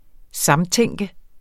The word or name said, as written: samtænke